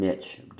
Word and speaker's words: niche: Some people say niche, but a lot of people say niche. So rich and niche do rhyme.